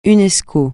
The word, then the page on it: UNESCO: francais